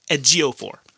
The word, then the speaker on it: Ejiofor: Ejiofor.